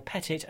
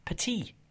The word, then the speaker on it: Pettit: Actually, I'm just confirming, it is Stephen Dale Pettit and not Petit, because it's crucial, you know? – You got it right from the off. I mean, that's the way it's pronounced in America. People instinctively kind of probably want to pronounce it like the footballer or, you know, the guy... – Emmanuel Petit, exactly. – Or the type, the wire-walking Philippe Petit as well. But it's Pettit, where I was born and raised, that's what they said, and that's the way I stick to it.